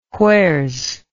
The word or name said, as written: wheres